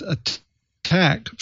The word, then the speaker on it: ATT&CK: Okay, so this item might sound a little inside baseball, but it has implications for just about every enterprise because the ATT&CK, that's pronounced attack framework from nonprofit corporation MITRE, is so widely used across the industry.